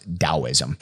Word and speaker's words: Taoism: Yes, it can be spelled with a D. It can be spelled with a T. Regardless, it is pronounced Taoism.